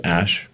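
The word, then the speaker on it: Æ: So, I mean, it's just X, the letter X, and then the AE is like pronounced Ash.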